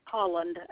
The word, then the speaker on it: Holland: Holland, H-O-L-L-A-N-D.